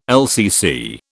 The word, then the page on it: LCC: British English